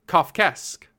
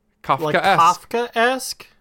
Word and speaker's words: Kafkaesque: Hold on, I looked it up earlier so I could say it. Kafkaesque. – Kafkaesque. – Yeah, Kafkaesque.